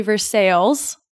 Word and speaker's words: Versailles: And I was like, well, to be very nice, it's actually Versailles. Everything is like two syllables here.